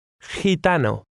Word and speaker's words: gitano: The combination G-I is pronounced in a similar way. Read word 7. – Gitano.